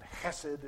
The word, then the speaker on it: hesed: It is the word in Hebrew known as hesed. I can't pronounce that right, you know, because you have to kind of clear your throat. But hesed is how it is nearly pronounced.